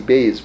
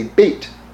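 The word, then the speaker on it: Beit (בית): You can pronounce, like, that's the Ashkenazi Beis, but you can say Beit. Beit is how you would pronounce it, you know, maybe more authoritatively, right?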